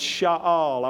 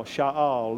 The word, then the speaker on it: sha'al: I looked this word up, and it's sha'al. I want you to make a friend of the word. It's a Hebrew word, and it's spelled S-H-A-apostrophe-A-L, sha'al. Let's say it together, sha'al.